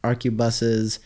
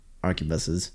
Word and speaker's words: arquebuses: 1 squad of Skitarii Rangers with 2 transonic arquebuses, transuranic. I've been saying that wrong. Arquebuses. 1 squad of Skitarii Vanguards with an Omnis Bex.